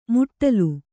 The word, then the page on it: ಮುಟ್ಟಲು: muṭṭalu